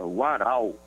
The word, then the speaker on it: Warau: A pronúncia é Warau.